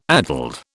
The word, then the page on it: addled: British English